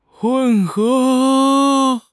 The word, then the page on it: 混合: HSK6